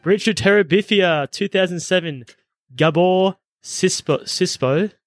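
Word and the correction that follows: Gábor Csupó: film. Shorty, was that good pronunciation? – Gabor Chupo. – Yes, not even close.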